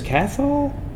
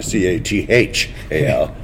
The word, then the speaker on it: Cathal: Is Cathal? – No, you don't pronounce the T.